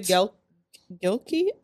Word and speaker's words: Gilkey: His name is Corey Gilkey. I don't want to mispronounce his last name. It's G-I-L-Key, like K-E-Y.